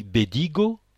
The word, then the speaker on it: Bedigo: Dans la langue provençale, le O final ne se prononce pas. Les noms se terminant par O sont des noms féminins. Li Bedigo, prononcé Bedig, les brebis.